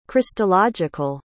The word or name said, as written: christological